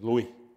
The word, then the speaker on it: Louis: But in French, the S is silent. So it is Louis.